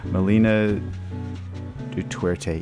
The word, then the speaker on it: Melina Duterte: Jay Som, local singer-songwriter Melinda Duterte. She, did I pronounce that correctly? I'm really trying to, I apologize. Melina Duterte, yes, thank you.